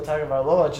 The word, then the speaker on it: Tagovailoa: Mac Jones is looking like Tua Tagovailoa just because of the weapons he has.